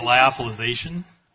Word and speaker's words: lyophilization: It's lyophilization.